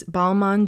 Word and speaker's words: Balmain: And the reason for that is because the French don't really pronounce the N's when it's attached to a vowel. So Balmain is B-A-L-M-A-I-N. A-I-N. So I guess that's why the N kind of disappears.